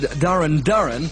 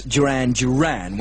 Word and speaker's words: Duran Duran: I said Darren Darren and Planet Earth at number 26, when of course it should be, as everybody knows, Duran Duran.